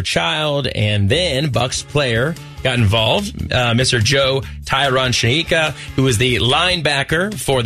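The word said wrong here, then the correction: Joe Tryon-Shoyinka: I would say his name correctly, Kevin, because I did not. – It's Joe Tryon Shoyinka. – There you go. Shoyinka. Even better.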